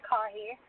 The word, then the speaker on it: Caughey: my stage name and real name is Amanda Caughey, and it is spelled C-A-U-G-H-E-Y. So whenever people were pronouncing it, they were saying things like coffee or collie, even though there's no L in my name,